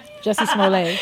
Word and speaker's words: Jussie Smollett: Jussie Smollett.